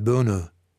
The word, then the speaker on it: Bono: I'm sure it's pronounced Bono in France. B-O-N-O, yeah, it's same as the singer and the Latin word.